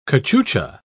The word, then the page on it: cachucha: British English